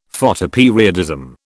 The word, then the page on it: Photoperiodism: British English